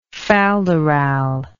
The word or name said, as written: falderal